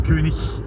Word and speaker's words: König: See, I pronounced the G. – Okay. König. Yeah.